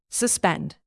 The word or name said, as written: suspend